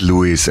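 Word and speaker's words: Lewis: Es ist Luis, aber mich schreibt man ja mit E-W-I-S und es gibt auch Louis mit O-U-I-S, das ist Louis.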